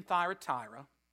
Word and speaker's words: Thyatira: The church in Thyatira, and it is a long I if you look at the translation, was suffering from bad influences in the congregation.